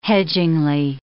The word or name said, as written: hedgingly